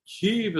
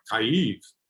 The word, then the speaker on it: Kyiv: My real pet peeve, if you watched all these Western media analysts calling it Kiev, I mean, Kiev, as I understand it, at least according to Google Translate, is the Russian pronunciation. The Ukrainian pronunciation is like Kyiv.